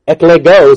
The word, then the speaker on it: eklego: Eklego is how it's pronounced in Greek.